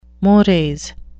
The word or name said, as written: mores